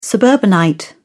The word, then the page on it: suburbanite: British English